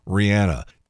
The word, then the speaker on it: Rihanna: It is Rihanna. Everybody else is saying it wrong with Rihanna. And all you have to do is think about it for a minute. What's the song called by Fleetwood Mac? Rhiannon. It's not Rhiannon. It's the same word.